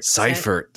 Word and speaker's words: Seifert: Seifert.